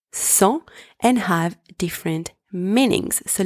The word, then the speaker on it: sang: Second word, sang, spelled S-A-N-G, means blood. In that word, the letter S is also pronounced S. The letters A-N together are pronounced en, and the final consonant G is silent. Third one, sang, spelled S-A-N-S, means without.